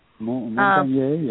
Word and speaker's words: Montagnier: Montagnier, yeah.